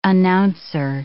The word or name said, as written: announcer